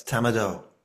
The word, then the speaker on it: Tamado: And I think he calls it Tamado. Is that how you say it? – Yes, Tamado.